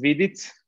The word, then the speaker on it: Vidic: So my last name is Vidic.